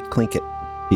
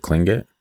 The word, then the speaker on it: Tlingit: I'm like, how do you say this? – And she said Tlingit? – Tlingit.